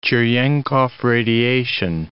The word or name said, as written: Cerenkov radiation